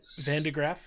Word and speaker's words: Van de Graaff: So that's where it's like, I think that's it's like gravity, G-R-A, and then the F is for focus. – Yep. No, yeah. You're right. – I was thinking about a Van de Graaff generator, which I don't know if you say that differently in America, but that's how I learned to pronounce it in high school. – Van de Graaff? Yeah, I mean, that's pretty close. that's how I was taught to say it, at least.